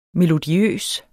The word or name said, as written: melodiøs